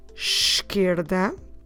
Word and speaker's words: esquerda: So if I want to say the left eyebrow, the word for left here is esquerda. Esquerda, starting with the sound of a sh, esquerda.